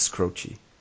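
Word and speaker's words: Skroce: En ze vroegen hem ook van, ja, maar hoe spreek je het nu uit? En hij zei, het is Scroogey.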